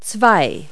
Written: two

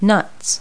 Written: nuts

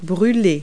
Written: burn

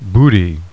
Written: BOOTY

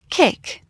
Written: kick

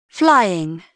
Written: flying

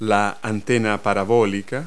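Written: satellite dish

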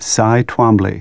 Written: Twombly

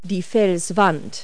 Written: rock face

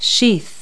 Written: sheath

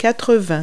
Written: eighty

